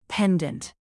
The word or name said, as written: pendant